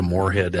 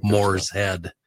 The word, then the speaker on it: Moorhead: David Freiberger from Roadkill Garage was in Moorhead picking up a car, and he referred to Moorhead as like Moore's Head. We're in Moore's Head, Minnesota.